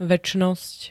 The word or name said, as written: večnosť